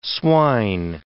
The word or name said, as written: swine